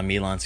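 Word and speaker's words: Milon: Yes, that same Chris Kohler who we just called out for not liking Milan Secret Castle. – Milan! – I don't care. I don't care. I'm going to call it Milan, so there. – Rhymes with pylons.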